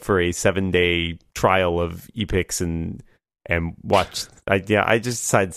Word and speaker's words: Epix: It's on, I don't know how to pronounce the service, Epix? Epix? – Mm-hmm. Epix.